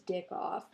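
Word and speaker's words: Dickkopf: D-I-C-K. – Yep. – K-O-P-F. – Yep. That's I that's even, right. Like, I searched all these words on, like, a pronunciation website so I could at least kind of do them justice. Mm-hmm. And then the last one, I was like, that says Dick Off.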